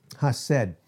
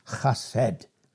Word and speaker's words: chesed: Hased uh, is, it's, it's spelled C H E S E D, hased. It's pronounced, you don't pronounce the C, hased.